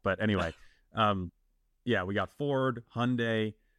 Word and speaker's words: Hyundai: I'm learning that it's not Hyundai, that it's Hyundai. – Rhymes with Sunday, yeah.